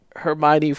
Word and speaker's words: Hermione: Hermione from Harry Potter was one, because it's spelled H-E-R-M-O-I-N-E. – Yeah. – I definitely wasn't saying Hermione when I was reading Harry Potter.